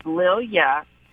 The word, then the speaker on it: Lilia: I'm going to start, you know, just letting people know this is how you pronounce it. It's Lil Ya, two syllables.